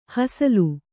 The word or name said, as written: ಹಸಲು